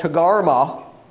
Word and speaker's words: Togarma: The next one that we have to work on is this place called Togarma. You know, I told you last week, in the Hebrew there are no vowels. In the Hebrew writing, it's just T-G-R-M, essentially, and has been pronounced Togarma for years.